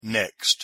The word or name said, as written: next